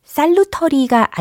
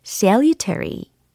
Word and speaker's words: salutary: 유익한, 효과가 좋은 이라는 표현이었는데 이게 발음이 salutary가 아니고 salutary, salutary. salutary 이렇게 하셔야 되겠습니다. 철자 기억나시죠? S-A-L-U-T-A-R-Y, salutary.